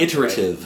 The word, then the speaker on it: iterative: Yeah, it's this irritative, it's this, God, I can't pronounce iterative. – It. Right. Iterative, thank you.